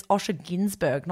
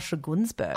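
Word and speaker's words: Osher Günsberg: This show showed me that it's Osha Ginsberg, not Osha Gunsberg.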